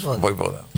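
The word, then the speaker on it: Vojvoda: Voivoda. – Que no. – Sí. – Sí, porque la J se pronuncia ahí.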